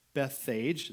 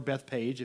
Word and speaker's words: Bethphage: They also had their whole city over there, which was the city of unripe figs, also known as Bethphage, there we go, or Bethpage if you want to say it like a Westerner.